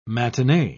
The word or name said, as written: matinee